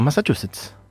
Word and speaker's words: Massachusetts: Por su familia adoptiva, descendientes del pueblo originario Mi'kmaq en Estados Unidos, quienes la cuidaron y criaron en esta palabra en la que más me cuesta pronunciar, Massachusetts. – 11 puntos, perfecto, Massachusetts.